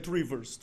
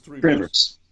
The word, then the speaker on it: Trivers: Uh, uh, uh Trivers. – Trivers.